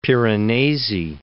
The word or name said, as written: Piranesi